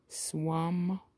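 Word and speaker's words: SWUM: They spell it capital S, W, U, capital M, Swam.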